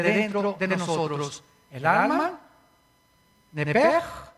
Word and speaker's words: nepesh: Así como suena, N-E-P-E-S-H. Nepesh. La palabra, la letra o las letras SH en hebreo se pronuncian en hebreo como J. Entonces se pronuncia nepesh. Y los judíos, como los alemanes, ponen mucho énfasis en el no voy a hacer otra cosa, ¿verdad? No se asusten. Nepej.